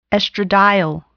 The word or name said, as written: estradiol